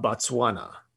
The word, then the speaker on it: Botswana: The real, we've all heard the term, Garden of Eden, has been traced to the African nation of Botswana. I think I'm pronouncing that right. I hope. – Yep, you got it.